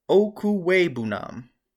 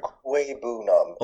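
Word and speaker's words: Okwuegbunam: One of the best tight ends in the 2020 draft is Albert Okuwebunam. – Are you sure you said it correctly? – That is correct. Albert Okuwebunam.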